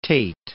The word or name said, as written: Tait